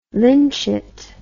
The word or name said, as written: lynchet